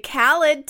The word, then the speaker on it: Khaled: I was going to say DJ Salad because that's how Miranda told me how to pronounce Khaled. – Oh, she said, just make it rhyme with salad because I'm an old person and I don't know how to pronounce it.